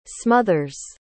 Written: smothers

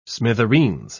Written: smithereens

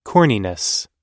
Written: corniness

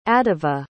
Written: adiva

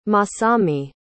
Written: Masami

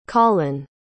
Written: collin

